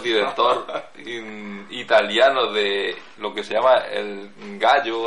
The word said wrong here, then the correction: giallo: o algo así, que es el cine cutre. – Giallo, amarillo. – Bueno, yo lo llamo gallo porque se escribe gallo.